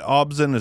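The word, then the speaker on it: ObZen: Is it Obzin? Is that how it's pronounced? – Yeah, Obzin's my first favorite album.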